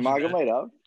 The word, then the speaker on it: Nurmagomedov: And then Khabib Nurmagomedov. I probably butchered Nurmagomedov.